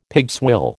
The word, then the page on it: pigswill: American English